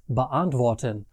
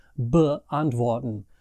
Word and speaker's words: beantworten: The only other thing that's special about inseparable prefixes is that the emphasis in the pronunciation is on the main part of the verb and not the prefixes. So it's beantworten, bedanken, bekommen, and so on, not beantworten, bedanken, bekommen. You get what I mean?